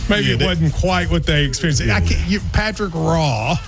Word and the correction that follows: Roy: There's no R. – Why do you keep throwing in an R? – But there's an R in the name, though, right? – Yeah, R-O-Y, but it's pronounced Wah.